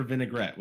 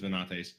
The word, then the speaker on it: Venates: All right, so now questions for Vinaigrette, which I'm pretty sure is Venates. – I'm pretty sure also. As I mentioned, people pronounce it wrong all the time.